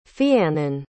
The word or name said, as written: fiannan